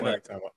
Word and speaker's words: ivermectin: You know, lately they've been talking about the monoclonal antibodies and the what's it called, ivermectin, if I'm saying it right. – Yeah, ivermectin.